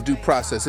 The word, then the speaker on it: Deux Process: Big up to Vice Verse, Chief Neck, and Sean Dub, three dudes in Deux Process. It's pronounced Deux Process, but it's spelled like the Frenchies spelled Deux, two. D-E-U-X, Process. Deux Process.